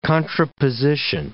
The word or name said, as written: contraposition